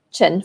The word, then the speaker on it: Tan: So, for example, if her family name is uh, Tan, spelled T-A-N, pronounced Chen, for example, Chen Li Mei, Chen Li Mei, Chen would be at the front, and then Li Mei.